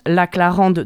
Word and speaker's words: La Claranda: on prononce La Clarande depuis le début, puisque ça se prononce comme ça. – C'est ça. – Mais en réalité, ça s'écrit La Claranda.